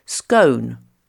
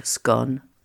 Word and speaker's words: scone: Et dans le sud-ouest et le centre de l'Angleterre, la seconde prononciation s'impose scone, comme home. » Mais on peut ajouter aussi l'origine géographique des classes supérieures qui privilégie la prononciation du nord d'Angleterre et de l'Écosse, donc scone comme gone.